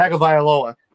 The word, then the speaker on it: Tagovailoa: Tagovailoa.